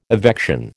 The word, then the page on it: evection: American English